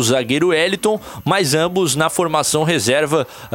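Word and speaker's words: Heliton: Sobre o Eliton, ele tem 23 anos, não é o Wellington, não tô é. falando errado. É, é H-E... Com acento, é, acento, com acento L-I-T-O-N.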